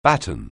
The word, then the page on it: batten: anglais